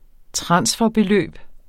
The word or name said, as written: transferbeløb